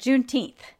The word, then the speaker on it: Juneteenth: And she goes, "Juneteens? June what? – The what? – Yeah, like did I say it wrong?" Kind of a thing. And I was like, "Juneteenth."